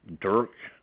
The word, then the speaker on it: Dirk: Dirk, D-I-R-K, Hushbeck, H-U-S-H-B-E-C-K.